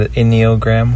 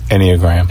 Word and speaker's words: Enneagram: What enneagram? How do you say that? Enneagram. Enneagram.